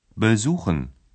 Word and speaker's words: besuchen: На ці префікси не падає наголос. До таких префіксів належать «be» або «er». – «Besuchen», «erzählen».